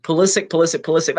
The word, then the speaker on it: Pulisic: Like, it's always Pulisic or Pulisic. I forget. That's how he pronounces it. Pulisic.